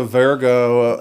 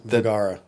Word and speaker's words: Vergara: I didn't know that Sofia Vergara and what the Vergara.